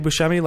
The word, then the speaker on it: Buscemi: And I'm Andy Buscemi. Last name is spelled BUSCEMI.